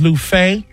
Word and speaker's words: Luffeigh: Last name is Lufay. L-U-F-F-E-I-G-H. And one F is silent.